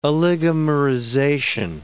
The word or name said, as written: oligomerization